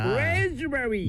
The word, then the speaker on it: raspberry: 이 P는 거의 발음하지 않습니다. 이 Raspberry라고 하면 안 돼요. 네. – 내가 할 줄 알았죠. – 네. Raspberry. 그래서 할 줄 알았다고요. – Raz에 이 강세를 줘야 됩니다.